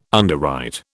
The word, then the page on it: underwrite: British English